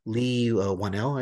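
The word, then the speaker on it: Leigh Whannell: Now, the Wolfman is going to be coming from Blumhouse and Universal, and Lee Whannell, I believe is how you pronounce his name.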